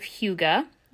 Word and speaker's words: hygge: We can embrace the Danish concept of hygge, and that's spelled H-Y-G-G-E, but pronounced as if it's spelled H-O-O-G-U-H.